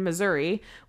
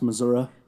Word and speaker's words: Missouri: Missouri where Cam grew up. – It's pronounced Missouri. – Sorry, Missouri.